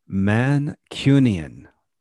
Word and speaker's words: Mancunian: Okay, pronounce that slower for our listeners. – Mancunian. – Mancunian. – Yes. – Like a mannequin.